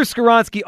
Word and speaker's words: Skoronski: So at number 10, so the Eagles' first pick, the number 10 overall pick, Peter, how do we say this? Skoronski. Peter Skoronski. – That's the one name that I got right since I've been here.